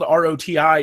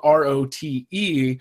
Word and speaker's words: Rote: It turns out that it's not really supposed to be spelled R O T I, it's supposed to be spelled R O T E, and thus it would be pronounced rote, not roti.